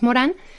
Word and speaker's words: Morin: Pues mira, está nuestra página web que es Colegio Edgar Morán, se escribe Morín con I, lo pronunciamos Morán, punto edu punto mx.